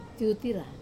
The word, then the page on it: tutira: Wamesa